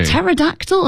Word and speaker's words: Pterodactyl: Pterodactyl, another silent P.